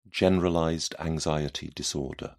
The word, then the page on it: generalized anxiety disorder: British English